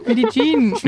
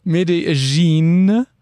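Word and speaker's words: Medellín: Also wir sind gerade in Medellin. – Medellin. – Vielleicht machen wir lieber statt der fünf Fun Facts die richtige Aussprache. – Medellin.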